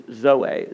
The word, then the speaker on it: zoe: Life, in the Greek word for life here, is zoe, Z-O-E, zoe. It's actually pronounced with a D, zoe, zoe.